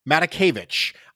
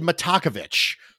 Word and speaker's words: Matakevich: I have made the mistake of emphasizing the wrong syllable in his name more times than I can count. I have said Matakevich so many times, and it's just, I'm putting the emphasis on the wrong syllable, Nate, and it's just, it's really problematic. So that one hurt my brain. – Yes. Yeah. Matakevich is what I'm supposed to be saying,